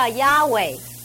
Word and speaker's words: Yahweh: 那这个名呢，在希伯来原文只是四个字母 YHWH 是不能发音的，因为全部是子音，所以这个犹太人就把它加上了母音 YHWEH，所以叫 Yahweh。